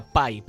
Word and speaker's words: Bai: Se escribe Bai, pero se pronuncia Pai. – Pai.